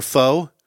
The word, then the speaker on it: pho: Oh, that's the Vietnamese dish that looks like pho or pho. Mm-hmm. It's pronounced pho.